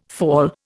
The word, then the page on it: fall: Indian